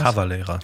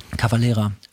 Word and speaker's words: Cavalera: Das erste Mal habe ich solche Gitarren, oder diese Gitarren, glaube ich, äh, äh, ähm, Max Cavalera, wie, wie, wie, spricht man den Namen aus? Cavalera. – Cavalera.